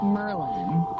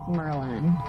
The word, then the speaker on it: Merlin: Yeah, it's Merlin. – It's not Merlian. – No, it's Merlin. Merlin, I feel like Merlin is a much actually more straightforward pronunciation because it's, you know, the wizard.